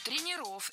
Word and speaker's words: тренеров: Тренеров – это неверно. Слово «тренер» имеет неподвижное ударение на основе, поэтому сохраняем это ударение на первом слоге во всех формах. Тренеров, тренером, тренерами. Похожая ситуация и с другим названием профессии.